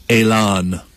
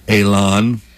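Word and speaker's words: élan: Elan. That's E with the accent over it. – Uh-huh. – L-A-N. Elan. – Oh. – E with the accent over it means it's pronounced A. – Okay. – Or I. Elan. A-Y. It's borrowed from the French.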